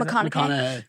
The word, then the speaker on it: McConaughey: How do you say McConaughey.